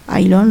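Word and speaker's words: Ayllón: la cantante y compositora peruana Eva Ailón, lo dije bien. Ailón, así es.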